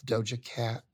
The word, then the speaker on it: Doja Cat: Doja Cat.